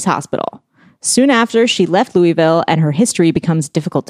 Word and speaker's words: Louisville: She then traveled to Louisville. Why do I not know how to say that right now? – Because there's an S in it.